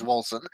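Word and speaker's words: Wolcen: Is the official pronunciation, okay. Is Wolsen.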